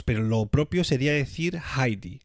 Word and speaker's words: Heidi: Heidi es la, la pronunciación española de este personaje. En alemán, el diptongo EI se pronuncia como AI. Es decir, que si lo pronunciásemos correctamente en alemán, tendríamos que decir siempre Heidi.